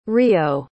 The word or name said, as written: rio